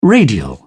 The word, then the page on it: radial: British English